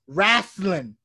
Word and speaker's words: wrastlin: Right, and if you're not from the South, it is pronounced wrestling. Wrestling. W-R-A-S-T-L-I-N. Wrestling.